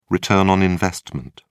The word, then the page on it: return on investment: British English